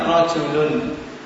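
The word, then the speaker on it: rajulun (رجلٌ): Contohnya misalnya rajulun. Rajulun. Ya, pada kata rajulun lamnya harakatnya apa? Dhani. Dhammah tanwin, ya kan? Ketika membacanya seakan-akan setelah lam itu ada nun. Nun dhammah, lam dhammah kemudian nun yang sukun.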